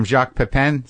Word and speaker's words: Jacques Pepin: But okay, so what do you, so you got the knack, you got the basics from Jacques Pepin.